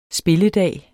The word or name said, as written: spilledag